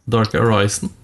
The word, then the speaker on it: Dark Arisen: Hvordan uttaler man det? Dark Arisen, ja det høres riktig ut.